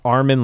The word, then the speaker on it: Armin: Armin, like Armenia. Danilian, like Danilian. – Like a chameleon.